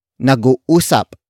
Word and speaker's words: nag-uusap: Nag-uusap pa kami.